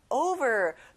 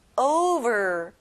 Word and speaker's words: over: 덮이도록 위에, over. 친구들, over가 아니라 o 발음이 나요. Over.